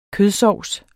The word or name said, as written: kødsovs